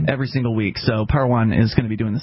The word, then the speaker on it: Parowan: Or Parowan, I believe. The O is pronounced as an A, I think.